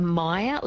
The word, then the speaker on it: Meyer: Elle has texted through and she's asked a question, about Myoria? She says, does the iron issue apply to Myoria as well? – So maybe a Meyer lemon, so M-E-Y-E-R. – That's it, yes. Excuse my bad pronunciation of it.